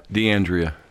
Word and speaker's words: DeAndrea: Is that how you say it, Mike? – Yes. – DeAndrea. Because I wasn't sure if it's Italian or French. – It's Italian. – Okay.